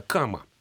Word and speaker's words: кама: Ирина, вы почти правильно назвали, ну не кади, а кама.